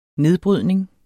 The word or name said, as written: nedbrydning